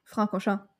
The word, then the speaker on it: Francorchamps: And they used, they used public roads between the towns of Francorchamps, right? Did I say that right? – Francorchamps.